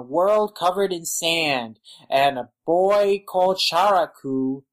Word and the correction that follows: Chakuro: or Chakuro, I mispronounced that, he drives a giant vessel mud whale in the sand.